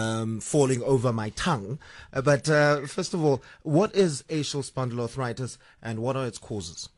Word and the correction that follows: axial spondyloarthritis: Okay, so the correct pronunciation is axial spondyloarthritis.